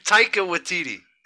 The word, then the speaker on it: Taika Waititi: I mean, Takai Waititi. I don't know if I'm saying that name right. Probably butchered it. – Taika Waititi. – Taika Waititi. I'm sorry.